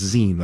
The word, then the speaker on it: zine: Somebody says Zine is pronounced zine, like magazine.